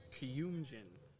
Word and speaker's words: Kuyumjin: Kumujidin. – It's actually my cousin. It's Kuyumjin. – Kuyumjin. – I can't spell it, though. – K-O-U.